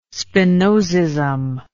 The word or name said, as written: spinozism